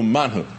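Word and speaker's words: man hu: Really, literally in Hebrew, manhu, they would pronounce it.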